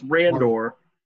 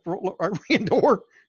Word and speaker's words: Randor: So King Reynard. And then, you know, and then Queen. – I think it's Randor. – Whatever. Randor.